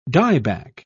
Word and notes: dieback: British English

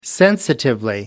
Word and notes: sensitively: British English

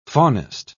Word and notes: faunist: British English

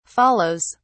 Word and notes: follows: British English

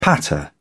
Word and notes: patter: British English

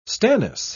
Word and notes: stannous: British English

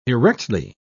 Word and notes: erectly: British English

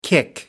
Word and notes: kick up: American English

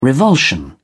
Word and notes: revulsion: British English

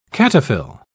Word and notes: cataphyll: British English

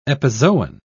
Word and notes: epizoon: British English

